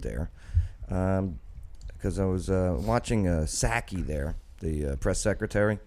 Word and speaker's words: Psaki: White House press secretary Jen Psaki, and plus she has a silent P in her last name.